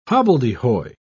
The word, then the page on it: hobbledehoy: British English